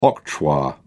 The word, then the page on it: octroi: British English